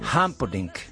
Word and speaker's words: Humperdinck: Mira que ha sido difícil para los españoles pronunciar este nombre. – No, muy fácil. – Humperdinck. – No, es que es Humperdinck.